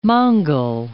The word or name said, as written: Mongol